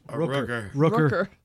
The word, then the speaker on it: Rooker: As exhausting as it was, Michael Roker. – Rooker. – I keep saying Roker.